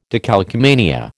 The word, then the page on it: decalcomania: American English